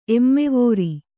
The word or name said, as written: ಎಮ್ಮೆವೋರಿ